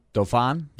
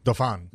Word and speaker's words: Dauphin: Is it Dauphin? Is that how you say his name? – Laurent Dauphin. – Dauphin. – Yeah. Dauphin? – Yeah. He was one of those. – Just depends on how French you want to go.